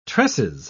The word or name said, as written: tresses